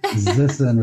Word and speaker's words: Zissen: Did I say that right, David? – Zissen with a Z.